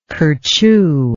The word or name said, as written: kerchoo